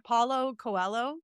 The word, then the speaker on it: Paulo Coelho: Paulo Coelho.